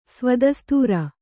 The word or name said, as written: ಸ್ವದಸ್ತೂರ